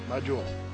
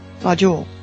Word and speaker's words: Majdul: How do you say that? Maj Adul? In the guild chat. M-A-J-D-U-L. – Majul. – Majul.